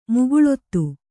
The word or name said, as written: ಮುಕುಲಿತ